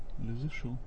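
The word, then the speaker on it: Les Échos: «Ля Эхо», да, по-французски, кто знает, как, потому что вот «Ля Эхо», газета французская, как произносится? – «Ля Зешо». – Нет, вот «Эхос», вот написано «Эхос». – «Ля Зешо». – Да, хорошо.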